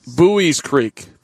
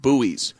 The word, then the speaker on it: Buies Creek: And Winston-Salem went down 6-5 to Buell's Creek, which I'm still learning how to pronounce. Buies Creek. Thank you, Andy Mazur. Buies Creek. Did you know that? It's B-U-L-E-S, Harry, but it's pronounced Buies.